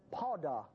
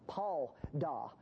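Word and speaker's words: padah (פָּדָה): It's the Hebrew word padah, padah, P-A-D-H, but it's pronounced kind of like P-A-W, padah.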